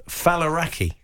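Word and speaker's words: Faliraki: Your new show is called Falaraki. – Yeah. You pronounced it correctly. A lot of people don't.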